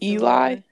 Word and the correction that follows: Ely: is it Duluth? It's pronounced Ely. Ely?